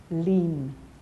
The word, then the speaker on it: lien: In French, it's lien. Hmm? – Yeah. – Yes. But I think in English, we pronounce it as lien, L-I-E-N, lien.